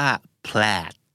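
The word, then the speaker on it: plaid: หน้าตาจะเหมือนเพลทแต่ออกเสียงว่าแพลทสระแอนะครับ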